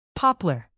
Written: poplar

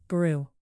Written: brew

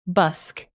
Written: busk

